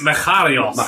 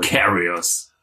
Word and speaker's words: Macarius: Macharius. Wie würdet ihr das aussprechen? – Ich würde es Macharius aussprechen. – Macarius. – Man kann es auch Macarius sagen.